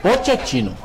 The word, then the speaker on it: Pochettino: Ô Anderson, como é que é a pronúncia exata do nome do, do nome do Poquetino? O Voivoda falou Pochettino.